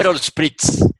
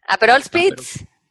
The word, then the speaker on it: Aperol Spritz: Aperol Spritz. – Aperol Spritz.